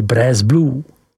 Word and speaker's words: Bresse Bleu: ale také výrobou prvotřídního sýra Bresse Bleu, vyslovuj Bres Blue.